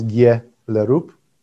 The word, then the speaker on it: Gjellerup: No, if you if you want an entertainment book to read, Karl Gjellerup. It's written G J E That's how Danes pronounce Gjellerup.